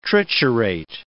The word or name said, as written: triturate